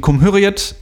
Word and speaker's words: Cumhuriyet: Ich glaube, es ist Cumhuriyet, weil ich glaube, also jetzt meine begrenzte türkische Kenntnisse bei mir, aber ich glaube tatsächlich, das C ist immer J oder so, wenn dieses kleine der Kringel drunter ist.